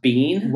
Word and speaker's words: Biehn: Third and final actor that I'm going to have you recast is, you've mentioned him already on the show, but Michael Biehn as Lieutenant Coffee, not spelled C-O-F-F-E-E, but C-O-F-F-E-Y. – Is it pronounced Biehn? – Why? That's how I've always pronounced it. B-I-E-H-N.